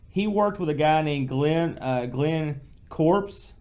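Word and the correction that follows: Corpes: It's I remember reading that his name is pronounced Corpus.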